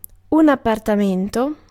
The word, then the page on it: apartment: [ʌ.ˈpɑɹt.mɛnt]; /əˈpɑː(ɹ)t.mənt/; /ə.ˈpɑːt.mənt/